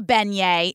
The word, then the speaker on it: beignet: I was there, mimosa in hand, shaking hands, rubbing elbows, saying hi, having a beignet. Is that how you pronounce it? – You did good. – Having a beignet and eating some crawfish and gumbo.